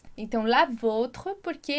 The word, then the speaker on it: vôtre: E aqui tem um detalhe de pronúncia importante. A gente diz lá pro adjectif possessif, a gente vai dizer votre. E aqui vem o accent circonflexe para fazer com que a gente pronuncie votre. Então, olha lá a diferença. Votre, sem o acento, votre.